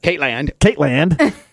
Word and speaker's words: Katelyn: And then Caitlin, you didn't say it right. – What's that? – Caitland.